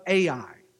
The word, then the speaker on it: Ai: Ai. That's how it's pronounced, the two letters, Ai.